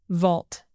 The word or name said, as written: vault